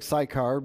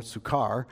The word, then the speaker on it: Sychar: Now, it looks like Sychar, but if you look at the Greek, at least the Greek pronunciation would be more Sychar.